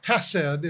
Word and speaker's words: hesed: It's pronounced hesed in the Hebrew language, and it speaks of just God's covenant love towards Israel and to all the nations.